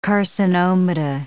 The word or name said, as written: carcinomata